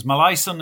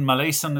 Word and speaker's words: Molaison: Uh, there are uh, you get various pronunciations. Malaison is one, Malaison is another. It's it's uh, a Cajun name. His his his father side of his family uh, are from Louisiana, uh, and I believe his mother uh, was from Canada, but he was brought up in the US.